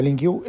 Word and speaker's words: LINQ: Se pronuncia LinkQ, L-I-N-Q. Es una de las cosas,